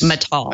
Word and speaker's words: Matal: Am I pronouncing that right, Mike? How do you pronounce the name of the head of the USPTO? – I have heard it pronounced that way, yes. – Mittal. – I've heard multiple pronunciations. – M-A-T-A-L.